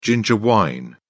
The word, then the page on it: ginger wine: British English